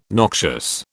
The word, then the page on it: noxious: British English